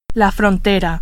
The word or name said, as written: frontera